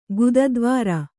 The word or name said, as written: ಗುದದ್ವಾರ